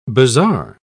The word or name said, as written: bazar